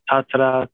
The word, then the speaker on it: Tatra: Is it pronounced the way it's written, the Tatra beer? – That's right. Yeah. So in Polish, there's a tendency to roll the R, but the pronunciation is the same. So Tatra. It's okay.